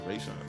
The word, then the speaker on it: Rashawn: Like, so if you're going to add the Ray, it'll just be Rayshawn.